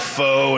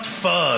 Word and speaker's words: pho: It's spelled P-H-O, and people are like, it's pronounced pho, and they get all upset.